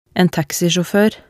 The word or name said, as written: en taxisjaafoer